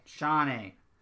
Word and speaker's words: Chane: And the main person in that faction is Huey's daughter, Shane? – Chane. – Dang it. – It's spelled Chane, but they kept calling her Chane.